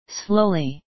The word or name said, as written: slowly